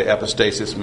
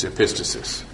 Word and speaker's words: epistasis: And this is an example of an often mispronounced word called epistasis. So when you hear someone say epistasis, make fun of them. It's epistasis. Actually, they might both be allowed.